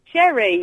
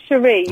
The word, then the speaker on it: Sheree: Sherry. – And is that's with two R's, is it? – No, it's actually S-H-E-R-E-E. – Ooh. – It's pronounced Cherie, but chavvy.